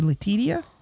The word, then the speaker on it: Letitia: Letitia. Or Letitia? – It's Letitia, but it does look like Letitia.